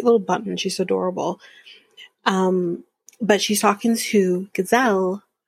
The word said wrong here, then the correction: Giselle: who or I should say sorry, saying her name wrong, Giselle,